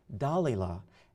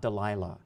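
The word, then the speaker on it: Dalila: Let me just digress here for a moment and explain to you why I pronounce her name Dalilah and not Delilah.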